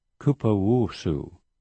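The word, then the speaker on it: Cupuaçu: And also, have you heard of Kapuoko? No? Okay. It's a superfruit from Brazil. Now, if you're in Brazil, you'll find it. It's great, and it's called, it's pronounced Kupuaçu, but it's spelled C-U-P-U-A-C-U-U, and it's very well known in South America, and it does a lot of good inside the body.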